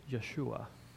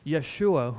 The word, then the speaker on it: Yeshua: The letter J, Justin or Joshua and all that, that's a gift to us from the English language. The way you would pronounce this is Yeshua.